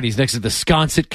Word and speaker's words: Sconset: Ah, that's pronounced Sconset. – Yeah, Sias Sconset is the, but they call it Sconset.